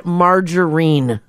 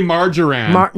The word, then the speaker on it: margarine: Do you know how I knew you weren't from Alberta? Is because you said margarine. – And you say something else here? – Margarine. Yeah, they butcher it. They say margarine.